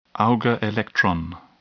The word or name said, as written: Auger electron